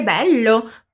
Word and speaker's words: bello: Você já deve se lembrar que quando temos uma dupla consoante, o som dela é mais alongado, né? Então vamos treinar só essa parte. Fala pra mim. Bello. Bello. Ótimo!